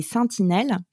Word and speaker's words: Sentinelles: C'est via le biais d'une plateforme qui s'appelle les Sentinelles, donc à bien prononcer avec « saint » et pas « sentinelle », mais il y a un petit jeu de mots.